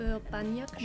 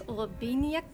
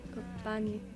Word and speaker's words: Urbaniak: We know it's Urbaniak. – Mm-hmm. – Urbaniak. – Short A. – Not Urbaniak. Not not like maniac. – No. – Okay. – Urbaniak.